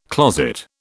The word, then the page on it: closet: British English